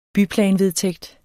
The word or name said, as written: byplanvedtægt